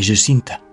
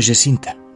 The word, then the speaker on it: Jacinta: Francisco y Jacinta, dicen los portugueses. Le pedí a un amigo portugués que me dijera cómo se pronunciaba. Pues Francisco y Jacinta, ¿no?